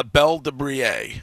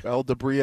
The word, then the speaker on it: Belle de Brie: How do you say that again? Belle what? – It's French. – Uh-huh. – Belle de Brie. – Belle de Brie. – Yes.